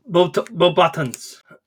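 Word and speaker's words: Beauxbatons: it's very close. But it's Bow. It's. I don't think they. Maybe they do pronounce it like that. But yeah, it's B E A U X B A T O N S. Is how you spell it.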